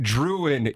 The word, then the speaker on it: Drouin: It's pronounced Druin, you Villahuso doppelganger.